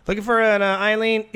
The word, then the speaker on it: Aileen: Her name is A-I-L. I'll spell it first and you pronounce it. A-I-L-E-E-N. – Isn't that Eileen? Aileen? – Aileen. – Aileen? – Not Eileen.